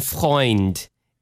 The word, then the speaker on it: Freund: It's pronounced Christine Freund. And it's German for friend.